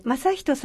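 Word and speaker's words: 政人: 政治のせいに人と書いて正人さんとおっしゃるそうですが、はい、実はお兄様も人がつくんですってね。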